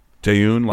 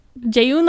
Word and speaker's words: Jaeyoon: Jay Eon? Yeah, like spoon. – Jaeyoon like spoon. Jaeyoon like spoon.